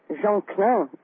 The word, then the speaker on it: Jean Klein: So you were talking about Jean Klein, as I would pronounce it in a French way.